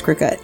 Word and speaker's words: Cricut: It's spelled C-R-I-C-U-T, but a lot of people call it cricket. Call it a cricket, okay. – But it's cricket.